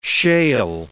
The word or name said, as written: shale